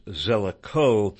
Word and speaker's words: Zelikow: His name, Zelikow, is pronounced Zelikow. It rhymes with go.